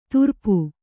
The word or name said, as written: ತುರ್ಪು